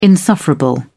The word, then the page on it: insufferable: British English